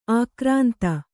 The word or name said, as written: ಆಕ್ರಾಂತೆ